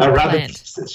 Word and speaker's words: Arabidopsis: but the Arabidopsis plant. – Arabidopsis, sure.